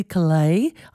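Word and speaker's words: Caillat: And counting her song in, American folk singer Colby Calais, I think the T is silent, Bubbly from 2007.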